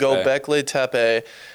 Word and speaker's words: Göbekli Tepe: Göbekli Tepe. – Göbekli Tepe.